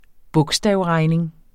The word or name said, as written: bogstavregning